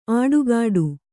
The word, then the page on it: ಆಡುಗಾಡು: āḍugāḍu